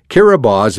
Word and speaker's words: Kiribati: Kiribati is the native pronunciation of Gilbert's.